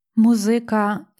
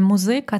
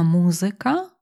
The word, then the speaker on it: музика: Музика зір блакитнооких. Знову ж таки, музика – це неправильний наголос, більш тут вживаємо як поетичний наголос. Правильно, музика, музика, зір, зір.